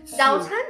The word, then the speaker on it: 早餐: Is my pronunciation correct? 早餐. – Almost there.